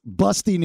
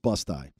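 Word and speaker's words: Busti: Bust Eye. I'm sorry. It's pronounced Bust Eye. – Bust Eye. – No, Bust it's eye. – Busty. – But it looks like Busty.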